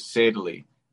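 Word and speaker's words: Saidali: And for me, my last name is Sadley, and it's spelled Said Ali. So they would always say it incorrectly.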